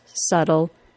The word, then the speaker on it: subtle: Your first lesson of this podcast, then, is that you can be confident in dropping the B sound from the words subtle, debt, and doubt. In fact, pronouncing the B sound is wholly incorrect.